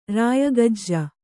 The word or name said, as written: ರಾಯಗಜ್ಜ